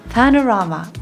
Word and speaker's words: パノラマ: 正しい発音お願いしますパノラーマ